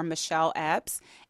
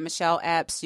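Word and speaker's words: Mashelle Epps: You can just search for Michelle Epps.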